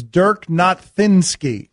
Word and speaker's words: Dirk Nowitzki: It's Dirk Nowitzki.